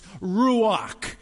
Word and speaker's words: Ruach: In fact, if you look at the original translation, Holy Spirit is pronounced Ruach.